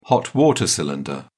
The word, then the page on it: hot-water tank: British English